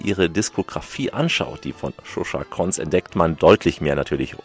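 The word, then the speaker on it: Zsuzsa Koncz: Shusha Konz, die Schreibweise, die erfordert Konzentration, will ich ihn gar nicht genau buchstabieren, aber Shusha Konz, so kann man es aussprechen.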